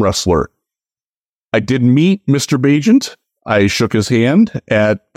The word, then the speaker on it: Bagent: It probably will be Tyson Bagent. Rhymes with agent.